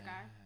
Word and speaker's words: Gahr: I do like a good Pinot Noir, mostly because it rhymes with my name. That's why I picked it. – It is in your bio. – Yeah. Pinot Noir, Sarah Gahr.